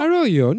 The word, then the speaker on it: Arroyo: Είναι A-R-R-O-Y-O Grande. Εγώ θα το πω. Αρόιο, ναι, καλά το είπε.